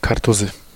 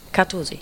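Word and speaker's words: Kartuzy: Kartusi. – Kartusi.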